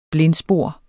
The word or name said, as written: BLINDSPOR